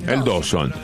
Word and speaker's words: Dawson: Dawson, ¿cómo se dice? – Dawson. – Es como lo dice Stoppelman,